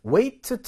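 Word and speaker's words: weigh: 그러니까 weight training 한다 이런 말은 들어봤죠? 그래서 weight라고 하는 단어에는 무게의 뜻도 있고 역기의 뜻도 있거든요. 그런데 그것의 동사형이 weigh예요. 그리고 이 gh는 발음이 없죠.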